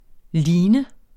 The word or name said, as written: lean